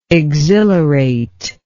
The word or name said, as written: exhilarator